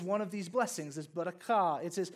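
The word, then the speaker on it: barakah (ברכה): I got critiqued in how I pronounced it in the first service, but it's like barakah.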